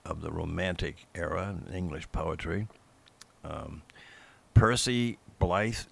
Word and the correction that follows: Bysshe: How do you say, it? Blythe, Percy Blythe Shelley. – Bysshe. – Oh, you say Percy Bysshe Shelley. Okay.